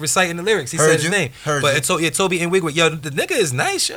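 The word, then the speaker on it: Tobe Nwigwe: Speaking of Houston, yo, I've been listening to Toby in Wigwe. – Toby Nwigwe? – Yeah, I ain't know how to pronounce it.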